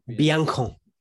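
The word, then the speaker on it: Biancone: Biancon. Apparently it's Biancon. It's not, there's no accent on the E.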